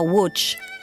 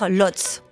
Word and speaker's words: Łódź: C'est ainsi que se prononce le nom de cette ville en polonais, mais j'espère que vous me pardonnerez de le prononcer à la française, comme le faisait d'ailleurs Rubinstein lui-même, c'est-à-dire Łódź.